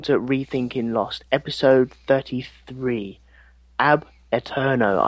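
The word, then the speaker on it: Ab Aeterno: Ab Eterno. – Well, that sounds so easy. – Ab Eterno, yep. – Would you go with that? – The first syllable is pronounced like Fonzie's A. Ab Eterno.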